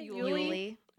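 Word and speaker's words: Uley: Uli.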